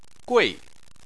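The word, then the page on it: gui4: tone 4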